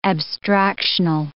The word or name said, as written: abstractional